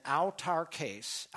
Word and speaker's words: autarkēs: Now, the Greek word that's used here for content, autarkēs is the way it's pronounced.